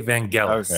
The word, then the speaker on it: Vangelis: This is a hard G, okay? Vangelis. Okay. All right. – Okay. – This does not rhyme with Los Angeles, okay? – Okay.